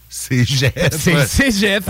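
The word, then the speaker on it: cégep: C'est Jeff. – Manifestement. – C'est un G.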